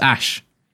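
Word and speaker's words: Æ: So AE is pronounced Ash.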